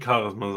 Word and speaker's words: Charisma: Also im Ring, finde ich, hat er trotzdem ein sehr krasses Charisma. Oder Charisma? War das eigentlich die richtige Aussprache davon? – Ich würde Charisma sagen. – Dann sage ich Charisma.